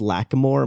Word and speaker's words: Lacamoire: But Alex Lacamoire, am I pronouncing his last name right? – Yeah, that's right.